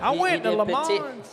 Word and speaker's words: Le Mans: Did you say Le Mans? – Le Mans. – Le Mans. – I went to Le Mans. – The N is silent.